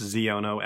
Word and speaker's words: Xiono: Yes, Ziono, X-I-O-N-O. That's how you say it.